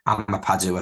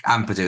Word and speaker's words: Ampadu: Obviously, Leicester have Ndidi, Drewsbury, Hall and Winks, but to have Ethan Apam, I think it's Ampadu, I think is how you say his name. I can't actually say his name. It's very weird. Ampadu, that's the one.